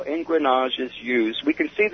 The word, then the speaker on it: engrenage: It's a French term, and it's spelled E-N-G-R-E-N-A-G-E, engrenage.